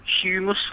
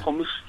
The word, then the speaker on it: humus: I think it's pronounced, like, humus, like, not hummus, humus.